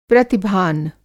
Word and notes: प्रतिभान: Hindi

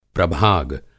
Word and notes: प्रभाग: Hindi

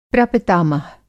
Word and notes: प्रपितामह: Hindi

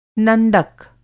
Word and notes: नंदक: Hindi

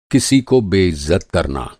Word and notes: बेइज़्ज़त: بے عزت

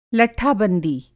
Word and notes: लट्ठाबंदी: Hindi

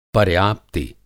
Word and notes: पर्याप्ति: Hindi

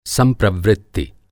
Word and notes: संप्रवृत्ति: Hindi

